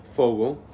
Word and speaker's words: Fogle: Fogle.